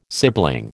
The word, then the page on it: sibling: American English